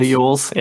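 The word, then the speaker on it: Elwes: Is it Yules? Yeah. – Like Yule Log.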